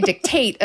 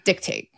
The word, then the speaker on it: dictate: You would say dictate and I would say dictate. – You're putting the emphasis on the first syllable and I'm putting it on the second.